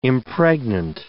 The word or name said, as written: impregnant